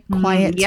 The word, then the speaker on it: quietude: Am I saying that right? Quietude. – Yes.